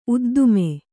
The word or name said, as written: ಉದ್ದಾಮ